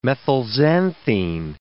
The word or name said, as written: methylxanthine